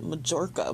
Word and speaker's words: Mallorca: But then I wanted to make sure I was pronouncing it right. Apparently some people pronounce it Majorca, but that doesn't sound right to me.